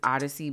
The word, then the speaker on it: Odicci: And if you want to look her up, her name is Odyssey, but it's spelled O-D-I-C-C-I Alexander.